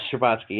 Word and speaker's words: Scherbatsky: Scherbatsky. Yes, Scherbatsky, yes.